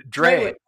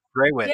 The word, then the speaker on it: Drewitz: No, Drewitz. Drewitz.